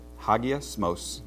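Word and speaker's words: Hagiasmos (ἁγιασμός): Greek word is hagamasos. I mispronounced that. Hagiasmos.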